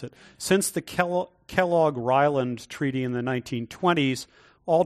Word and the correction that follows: Kellogg-Briand: you mean the Kellogg-Briand Pact.